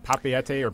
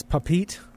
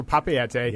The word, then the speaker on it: Papeete: Am I pronouncing that right? Is it Papeete? – It is. I initially pronounced it as Papete for a long time until someone corrected me. – A raising hand here. I said Papete as well. So Papeete.